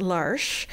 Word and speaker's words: L'Arche: but it's L'ARCHE, but it's pronounced Larsh.